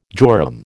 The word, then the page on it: jorum: American English